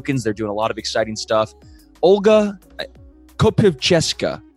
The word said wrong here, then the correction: Kopivcheska: I think I pronounced that right. Welcome to Crypto 101. – Thank you. Almost there. – Tell me, come on, the way you said it before sounded so beautiful, and then I just butchered it. – It's Kopivcheska. It's Russian, so...